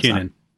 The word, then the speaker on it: Bakunin: How do you pronounce Bakunin.